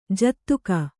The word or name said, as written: ಜೊತ್ತಗೆ